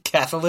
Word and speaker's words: Cathal: Cathal, look, I think you pronounce it Cathal, or it's Cathal. I don't know. – Cathal it is. – I don't know. Like Ethel with a C.